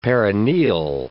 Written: perineal

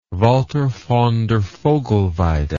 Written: Walther von der Vogelweide

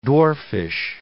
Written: dwarfish

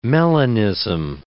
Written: melanism

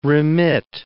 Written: remit

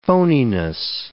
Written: phoniness

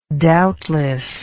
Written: doubtlessness

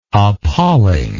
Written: appallingly

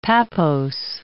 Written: pappose

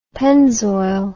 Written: pennzoil

